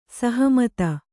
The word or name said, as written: ಸಹಮತ